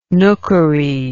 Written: nookery

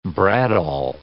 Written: bradawl

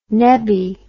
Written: nebbie

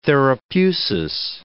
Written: therapeusis

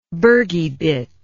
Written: bergybit

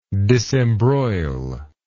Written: disembroil

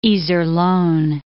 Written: Iserlohn

